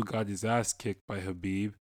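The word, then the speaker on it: Khabib: So on Twitter, he pretty much took a shot at, I want to pronounce his name, Habib. – It's just Habib.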